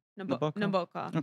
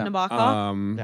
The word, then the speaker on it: Nabokov: So Nabokov. – Okay. Nabokov. – Yeah, I don't know. Anything. – It's that middle syllable that gets the punch, but you can do whatever you want with it.